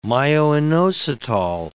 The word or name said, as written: myoinositol